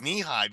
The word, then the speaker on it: Nehi: I didn't know that it was knee high because it's spelled NEHI, and it's, Nehi sounds very Hawaiian.